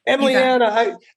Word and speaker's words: Emlianna: Did I say the name right? – Emlianna.